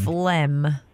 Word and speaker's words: phlegm: Phlegm. – That one sounds familiar. So let's see. I know it's kind of like that silent letter in there. So P-H-L-E-G-M.